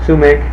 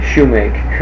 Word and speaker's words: sumac: How would you say that again? – Sumac. – Mac. That was after the pronunciation. That varies terrifically. – Yeah. – We'd call it probably shumac or shumac. I think that's what we'd say.